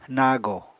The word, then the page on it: nago: Apatani